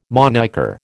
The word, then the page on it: monniker: American English